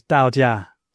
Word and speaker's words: Taoism: So for example, Taoism, sometimes spelt Taoism, well, that's the dominant spelling, actually is pronounced in Chinese Dao Jia, Dao, Dao with a D.